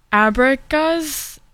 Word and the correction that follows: Abraxas: I think that's what it's pronounced. It's Abraxas.